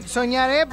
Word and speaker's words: Sognaré: soñaré, pero como se escribe como francés, ¿verdad? – Sognaré. – Sognaré. Pero se pronuncia soñaré, no te estreses.